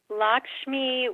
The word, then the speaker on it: Lakshmi: I'm not sure if I'm pronouncing her name correctly. And Vishnu, who were they in your life? – You are pronouncing them correctly. And Lakshmi was the spiritual leader of this group.